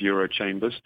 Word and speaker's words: Eurochambres: So Eurochambres is the, or Eurochambre, to use the correct pronunciation as you did, is the European umbrella organisation for chambers of commerce and industry across Europe.